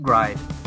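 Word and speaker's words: Gride: And there's one on the Dreamcast called Frame Grind. That rhymes with Glide, G-R-I-D, but my autocorrect keeps telling me it should be Grid, but it's a very customizable giant robot game.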